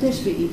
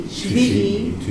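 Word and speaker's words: Shvi'i (שביעי): Rishon, like Sunday, Yom Rishon, the days of the week, Sheni, Shishi, Chamishi, Shishi, Shvi'i, not Teshvi'i, Shvi'i, Shmini, like Shemini, right, like the Parsha, Shmini, Ve'chi'i, right?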